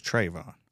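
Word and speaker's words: Trevon: Well, I mean, you come off of the last year with Tavon Diggs, right? I mean, did I say that right? Is it Trayvon or Tavon? – I think it's Trayvon. – Trayvon, yeah, so my bad.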